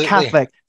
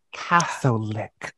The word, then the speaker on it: Catholic: Not a Catholic. Three syllables. Catholic. One thing worse than a witch in all of Salem.